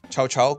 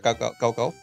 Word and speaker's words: Cao Cao: Eh, Chao Chao, creo que se pronuncia Cao Cao.